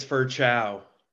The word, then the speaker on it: Ferchow: Actually, it's Ferchow.